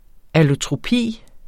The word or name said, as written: allotropi